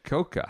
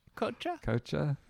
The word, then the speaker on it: Cocha: Koka. How do you spell that? – C-O-C-H-A. – Okay. Tommy Koka. – Kocha? – Yeah, that might be right.